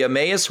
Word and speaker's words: Emmaus: I don't want to mispronounce the town. Emmaus, Pennsylvania. – Emmaus. – Yes. Thank you.